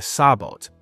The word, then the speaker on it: sabot: Very interesting one is M48 Slap, which fires a sub-caliber tungsten penetrator inside a sabot, pronounced sabo, giving it very high velocity.